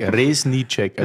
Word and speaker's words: Resnicek: Resnicek. Resnicek. Das Z spricht man aus, ja, wie ein genau. S und das genau. C wie ein Tsch.